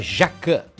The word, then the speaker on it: Jacquin: Deixa eu só retificar aqui que já a Daniela me passou aqui, é Jacquin, não Jacquin, né? O mestre-chefe lá, né? Então é Jacquin. Jacquin. É que se escreve Jacquin, né? J-A-C-Q-I-U-I-N. Mas é mestre, né?